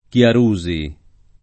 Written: Chiarusi

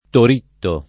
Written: Toritto

